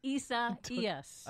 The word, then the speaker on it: Isaias: Hurricane, how do I say this, Desi Doyen? Isaias? – Isaias. – I see what you're doing. You're laughing Isaias. – At me. Hurricane Isaias.